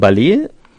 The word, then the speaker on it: Ballet: Das ist von Ballet, heißt die Band. Und reimt sich auf Array. – Ja, weil das anders ausgesprochen wird. Das ist ja französisch ausgesprochen.